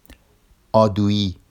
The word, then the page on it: ɑduji: Vafsi